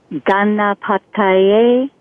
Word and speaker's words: Ganapataye: Gum is a seed syllable, G-A-M, and it's pronounced gum, G-U-M. Ganapataye, that's G-A-N-A-P-A-T-A-Y-E. – Okay. Ganapataye.